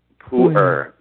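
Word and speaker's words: Pu'erh: You mentioned another category called Pu'er. – Pu'er.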